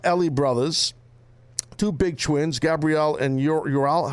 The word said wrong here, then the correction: Uriel: How do you say his name? Uriel. Uriel.